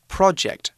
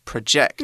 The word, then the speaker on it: project: One thing important to remember about the word project is that it should be pronounced project, not project. – Mm. – Project is a verb that means 放映.